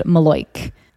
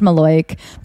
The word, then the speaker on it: malocchio: It's pronounced Maloik. It's spelled like Malocchio. It's pronounced Maloik, but it's an evil eye.